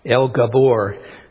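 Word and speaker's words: El Gibbor: Just looking at the phrase there, the mighty God, El Gabor, it's pronounced in Hebrew.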